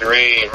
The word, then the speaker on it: Reem: Yo, it's Reem in the building. – My bad pronunciation. I got the pronunciation off a little bit. So Reem, it's just one syllable. Reem.